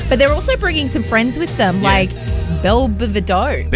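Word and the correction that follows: Bell Biv DeVoe: Belle Biv DeVoe.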